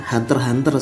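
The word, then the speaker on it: Hunter × Hunter: Hunter x Hunter guys. – Oke, Hunter x Hunter. Itu tuh kalau di versi apa ya? internasional internasionalnya tuh bacanya Hunter Hunter sebenarnya.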